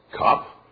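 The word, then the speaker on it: qoph: You have kop, which is a reverse C, pronounced like a K.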